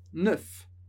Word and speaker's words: neuf: E a gente fala neuf. A gente lê o F no final.